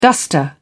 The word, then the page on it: duster: British English